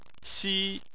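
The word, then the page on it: xi: tone 1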